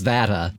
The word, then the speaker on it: Data: It's specified to pronounce it as Data.